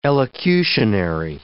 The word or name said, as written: elocutionary